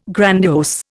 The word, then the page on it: grandiose: Indian